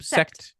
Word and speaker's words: Sekt: Am I saying it right? – Sekt. Correct. – Sekt. S-E-K-T.